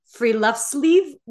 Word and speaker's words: friluftsliv: but it's friluftsliv, which is the translation is the open air life, which is the name of her second book.